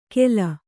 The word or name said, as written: ಖೇಲ